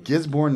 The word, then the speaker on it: Gisborne: Gisborne, New Zealand.